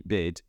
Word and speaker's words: BYD: And of course, some sort of bot told you that you pronounce the letters BYD, BYD. That is not necessarily how you pronounce a company name. Anyway, this company, BYD, BID, is China's largest electric vehicle manufacturer.